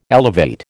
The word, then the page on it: elevate: American English